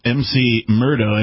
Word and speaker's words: McMurdo: Yes, MC Murdo, M-U-R-D-O, Station, operated by the United States. – Of course. – it's pronounced McMurdo, then, I see.